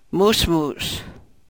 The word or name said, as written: musmus